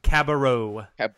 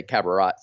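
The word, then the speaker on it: Cabarrot: Timotei Luwawu Cabarro. – Cabarro. Okay. I wanted to say Cabarot. So Cabarot.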